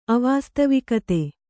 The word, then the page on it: ಅವಾಸ್ತವಿಕತೆ: Kannada